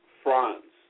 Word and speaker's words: Fronse: Okay, my name is spelled F R O N S E, pronounced Franz.